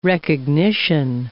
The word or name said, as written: recognition